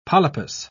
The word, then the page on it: polypus: British English